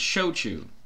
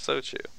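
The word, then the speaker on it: shochu: I even spelled it for you. It's S-H-O-C-H-U. – Oh, yeah, look at that. So, that's so-Chew. – Or pronounced so-Chew.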